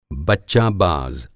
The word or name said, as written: बच्चाबाज़